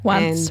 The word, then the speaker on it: Once: Once and rhymes with dunce, rhymes with C word.